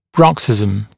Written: bruxism

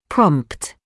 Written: prompt

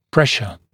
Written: pressure

